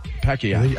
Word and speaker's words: Pacquiao: Pacquiao. – I think LeVar's right. – That's how they say it. Pacquiao.